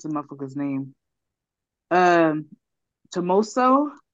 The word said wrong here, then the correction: Tommaso: Tommaso Ciampa. Thank you.